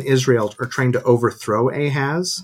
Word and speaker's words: Ahaz: Then we get Ahaz, how do you say that? – Ahaz.